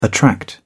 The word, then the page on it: attract: British English